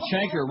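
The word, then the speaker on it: chancre: Speaking of chanker, rhymes with anchor.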